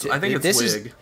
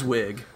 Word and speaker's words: Wiig: You know, like, I think a good example of a character who was definitely putting on a voice was Kristen Wiig, or Wiig, I think is maybe correct. – No, I think it's Wiig.